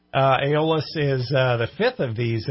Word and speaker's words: Aeolus: By the way, it's pronounced Aeolus.